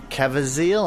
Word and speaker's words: Caviezel: So, you know, Jim Caviezel, I'm not sure how to pronounce Caviezel.